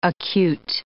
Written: acute

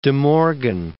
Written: De Morgan

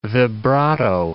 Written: vibrato

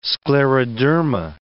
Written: scleroderma

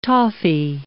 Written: toffy

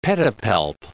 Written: pedipalp

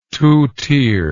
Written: two tier

